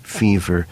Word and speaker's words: Feaver: In this case, we have a main character, Robbie Faver, spelt F-E-A-V-E-R, sometimes pronounced even by him, fever, and he is a liar.